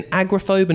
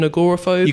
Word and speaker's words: agoraphobe: Is it an agoraphobe, an agoraphobe? – You can say either one.